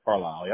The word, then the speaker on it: Carlisle: Carlisle, yep.